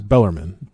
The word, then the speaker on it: Bellarmine: Bellarmine.